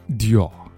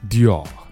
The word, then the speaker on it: Dior: Louis Vuitton 一定要注意那个 Louis 后面有个 S 但这个字母 S 是不发音的同样来自于法国 Louis Vuitton 屌牌呢 Dior Dior 如果你会法语的话用小舌音把最后一个音补上 Dior 如果不会那就 Dior